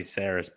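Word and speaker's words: Sarris: I think I'm saying his name right, Eno Saris. – It might be Saris, but yeah, Saris. Okay.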